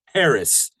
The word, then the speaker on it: Haracz: And again, my last name is spelled just like it sounds. Haracz. Harris.